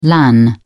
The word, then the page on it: lan: British English